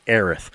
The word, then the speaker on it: Aerith: I'm saying her name right, Aerith, because the character felt like a stupid little teenage girl to me.